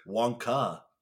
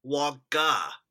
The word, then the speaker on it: Wonka: How do you say it? – Wonka. – What did I say? – Wonker. Like with an R. – Wonka.